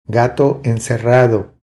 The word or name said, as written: Gato encerrado